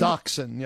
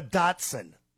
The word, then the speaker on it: dachshund: Is it dachshund? – Dachshund. Yes. You are correct. – I want to call it a Datsun. – Yeah. No, no. – Datsun is the car.